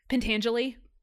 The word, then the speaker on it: Pentangeli: Pentangeli.